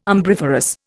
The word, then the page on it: umbriferous: Indian